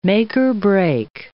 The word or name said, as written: make-or-break